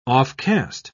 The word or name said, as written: offcast